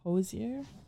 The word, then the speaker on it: Hozier: And is it Hozier? Is that how you say it? – Hozier? Yeah.